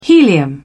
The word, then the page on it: helium: British English